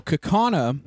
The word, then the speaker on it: Kaukauna: We weren't sure how to pronounce that. – It's Native American. – Whoops. – So Kaukauna.